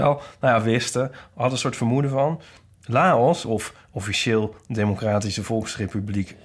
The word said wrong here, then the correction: Laos: Laos, gaat klemtoon, dit ging even verkeerd met de klemtoon. Laos, of officieel Democratische Volksrepubliek Laos...